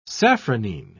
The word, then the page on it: safranine: British English